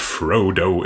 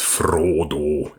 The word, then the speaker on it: Frodo: it's not Frodo, it's Frodo. And it's not Gandalf, it's Gandalf.